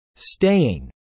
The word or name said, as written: staying